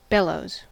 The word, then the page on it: bellows: American English